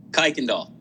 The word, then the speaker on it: Kuykendall: Kuykendall.